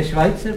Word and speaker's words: Schweitzer: No to se vyslovuje Švajce. – Co? – To je Švajcer, právě.